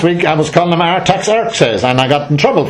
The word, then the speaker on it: Artaxerxes: That's the way you pronounce his name. I looked it up on the computer. Jacinta said, look it up, put it into Google, see how you pronounce it. And this girl said, Artaxerxes. So now I know. It's Artaxerxes. And the other fella is Xerxes.